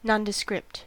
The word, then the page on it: nondescript: American English